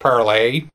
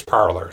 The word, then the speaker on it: Parler: Now, the point being, this post on Parler, and can I just Side note, I had assumed it was pronounced parlay because that's the French word from which it takes its name. Mm-hmm. It's not. I looked it up. It's parlor. They pronounce it parlor.